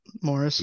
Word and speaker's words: Morris: Yep, Morris.